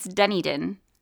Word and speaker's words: Dunedin: It's Dunedin.